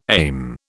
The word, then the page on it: aim: British English